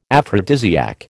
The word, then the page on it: aphrodisiac: American English